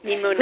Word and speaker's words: Mimuna: And, of course, you know, with your article you wrote in Kosher Inspired about Mimuna, am I saying it right? – Mimuna.